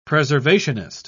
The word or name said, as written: preservationist